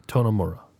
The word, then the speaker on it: Tonomura: Hiroshige Tonomura. – I think Tonomura sounds right.